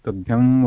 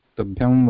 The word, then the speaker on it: tubhyam: whereas tubhyam varade, actually the anuswara shastra says that anuswara, to pronounce anuswara, the entire breath has to go to the nose, nasal passage only. Mouth should not be used.